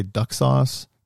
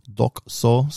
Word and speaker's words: Dokusou: Did it say duck sauce? No. You got to pronounce it right, and you got to hear it's Duck Soul. So D-O-K-U-S-O-U.